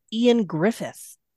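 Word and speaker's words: Ioan Gruffudd: His name is spelled like I-O-A-N, and then his last name is G-R-U-F-F-U-D, but it's pronounced Ian Griffith.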